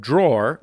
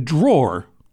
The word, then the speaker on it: drawer: Zach asks, "My girlfriend was raised by her mother to pronounce the word drawer like draw. However, I tell her this is incorrect and it should be pronounced with the er at the end, as it is spelled, drawer.